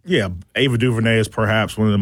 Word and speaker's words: DuVernay: He's got a consulting contract for Ava DuVernay.